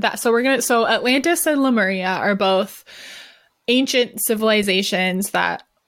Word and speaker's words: Lemuria: These are said to be connected to Lumeria too. Lemuria. I always say Lumeria, but it's Lemuria.